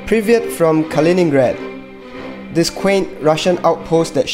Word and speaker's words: Kaliningrad: Kaliningrad.